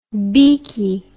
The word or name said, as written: beekie